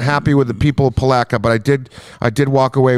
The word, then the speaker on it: Palatka: You pronounce it Palatka.